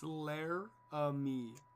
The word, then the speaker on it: Laramie: It's Laramie.